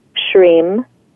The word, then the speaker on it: Shrim: So the mantra itself sounds like this: Om Shrim Lakshmiye Namaha.